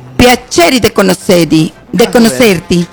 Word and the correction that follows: conoscerti: conoscerti. Conoscerti. – Sí, perfecto.